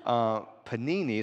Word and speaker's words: Panini: There's a 2017 Panini, is how you pronounce the card name, National Treasures Platinum, number 161, one of one.